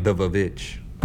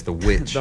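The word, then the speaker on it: The VVitch: But it looks like it's spelled like two V's. So it's like The Vavitch or whatever. But it's The Witch. – What? – The Vavitch. Because it's two V's that make a W.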